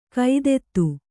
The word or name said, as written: ಖಾದಿತ